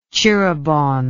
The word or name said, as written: Tjirebon